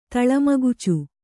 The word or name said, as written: ತಳಮಗುಚು